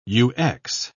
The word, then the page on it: ux: British English